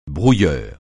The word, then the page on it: brouilleur: francais